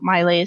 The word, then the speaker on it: Maile: So Maile is spelled M-A-I-L-E. My first name, Tanya, is T-A-N-Y-A.